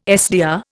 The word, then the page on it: SDR: Indian